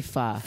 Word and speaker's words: pho: Well, we're supposed to say pho. – Pho, I'm sorry, pho.